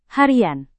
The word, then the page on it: harian: Indonesian